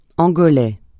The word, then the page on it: angolais: francais